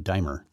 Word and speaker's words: D-dimer: Notice that pilots flying with abnormal D-dimer or D-dimer values, I'm not sure how to pronounce that, dimer, thanks, D-I-M-E-R, D-D-I-M-E-R, which indicate active blood clotting, are at elevated risk for pulmonary embolism, stroke, arrhythmias, cardiac arrest, and death while in flight.